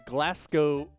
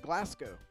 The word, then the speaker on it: Glasgow: Glasgow Rocks. And he says, by the way, listening to you guys pronounce Glasgow is hilarious. Ignore the W in Glasgow.